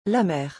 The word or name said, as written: La mere